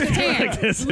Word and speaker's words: Catan: Yes, Catan. Lovely game. – I'm just glad you said the name right then.